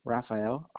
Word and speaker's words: Rafael: Rafael, R-A-F-A-E-L. – And last name? – Hernandez. H-E-R-N-A-N-D-E-Z. – H-E-R-N-A-N-D-E-Z? – Yes.